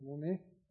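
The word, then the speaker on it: mone (μονή): It means mone, or it's pronounced mone.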